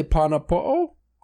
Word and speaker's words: pana po'o: How do you say the double O with the... I would say pana po'o.